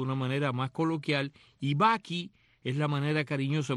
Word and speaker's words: bakkie: También se le llama Bucky en Sudáfrica. Y Bucky se escribe B-A-K-K-I-E,